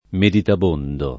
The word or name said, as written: meditabondo